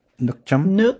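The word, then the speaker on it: nước chấm: So actually, you mentioned, how do you pronounce it? Nukjum? Okay. – Yeah, there you go. – I'm trying. I've also got Nukcham, so I don't, Nukjum. – Yeah. Jum. So nuk means water or liquid.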